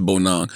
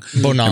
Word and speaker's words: Bonang: Remember when I was shouting out, I was pronouncing her name wrong because I was saying Bonang, but it's Bonang. – Bonang.